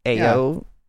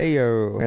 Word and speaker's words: Ayo: Ayo. – Yeah. Ayo. – Is that how you pronounce it? – I don't know. – It's A-Y-O. That's all it is.